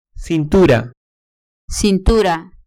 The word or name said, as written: cintura